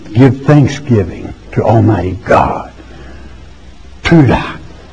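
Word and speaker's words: towdah: It's T-O-W-D-A-H. It's pronounced in that tongue, tuda, which is to say the putting forth of a hand.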